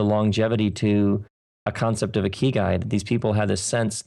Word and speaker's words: ikigai: It's a Japanese word, ikigai.